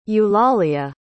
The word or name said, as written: eulalia